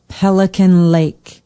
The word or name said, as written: pelican lake